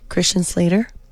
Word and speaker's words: Christian Slater: Dice, Christian Slater, ok. – ¿Oh, así se pronuncia? – Christian Slater.